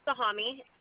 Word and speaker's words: Sahami: S-H-I-R-E-E-N. – And can I have your last name, please? – Sahami. S-A-H-A-M-I. – Can you spell that one more time? – S-A-H-A-M-I. – S-A-H-A-M-I? S-A-H-A-M-I.